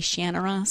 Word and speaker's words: Shannara: I don't know how he pronounces it. I grew up pronouncing it in my head, Shannara. The audiobook narrators all say Shannara.